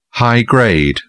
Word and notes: high-grade: British English

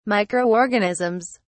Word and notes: microorganisms: British English

